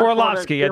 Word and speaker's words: Orlovsky: I think his name is Orlovsky. I'm sorry if I'm butchering his name. But Orlovsky, Dan Orlovsky, yep.